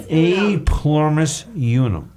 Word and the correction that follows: E pluribus unum: E pluribus unum. You did not say it right. E pluribus unum.